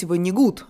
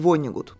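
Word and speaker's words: Воннегут: И по его версии, например, по-русски правильно говорить «ванигут», а не «вонигут», потому что это фамилия немецкого происхождения. Но поскольку я всегда изучала Вонигута как американского писателя и читала его в основном на английском, то и ударение в его фамилии я ставлю на первый слог.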